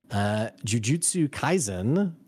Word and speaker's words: Jujutsu Kaisen: And then also my man Will, who is the master of breakdowns, just put out a video on an anime, Jujutsu Kaisen.